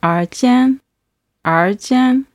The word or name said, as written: erjian